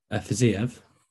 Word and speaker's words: Fiziev: Because how do you say his name? Ziev? – Fiziev. Rafael Fiziev. Yeah.